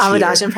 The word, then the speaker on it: Awadajan: Like Awadajan, is that the way you say his name? – Yep, Pratt. Yep, Awadajan Pratt.